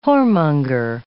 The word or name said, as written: whoremonger